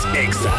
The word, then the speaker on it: EXA: Somos EXA. No se dicen éxitos, se dice EXA.